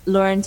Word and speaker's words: Lauren: And yeah, Instagram is Lauren Tichner. I know L-A-U-R-E, if you want me to spell it, it's L-A-U-R-E-N-T-I-C-K-N-E-R.